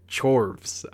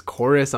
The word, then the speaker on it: Chorus: So it, like, when you saw it come up, I was like, Chorvs. I'm like, it's a weird name. But then when I saw that it was Chorus, I'm like, okay, that sounds a little better.